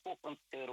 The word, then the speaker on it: хохломская: И правильное, конечно, произношение хохломская роспись.